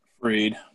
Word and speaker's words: Fried: Freed, yeah.